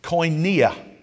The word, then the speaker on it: koinonia: And I've heard it pronounced several different ways, but the way that I pronounce it is koinonia. Koinonia. It's spelled with a K.